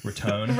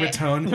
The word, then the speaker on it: Riton: R-I-T-O-N. – Got it. – I assume that's how it's pronounced. – Ritone. – Right. – Ritone.